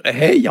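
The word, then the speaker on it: heiz: Hein, donc, il y a l'orge, hey, en breton, hein, y a un Z qu'on ne prononce pas.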